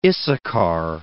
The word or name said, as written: Issachar